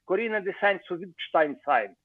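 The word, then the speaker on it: Corinna zu Sayn-Wittgenstein-Sayn: Карина де Сайн-Винштейн-Сайн. Вот так вот она произносится правильно. Потому что вдруг у нее в банке оказалось пару миллионов долларов.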